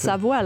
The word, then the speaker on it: Savoie: That sounds like it's believable to me if his last name is Savoy. – Savoie. It's pronounced Savoie, like the region.